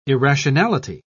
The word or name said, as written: irrationality